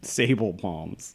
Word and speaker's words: Sabal: Sable palms. Sable palms. It's spelled S-A-B-A-L.